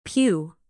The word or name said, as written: pew